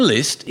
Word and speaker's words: Liszt: Brahms and Liszt is not Cockney rhyming slang for drunk because Liszt rhymes with pissed, but because Liszt was a foul-mouthed junkie and Brahms was a heavy drinker renowned for his shocking behaviour at respectable gatherings.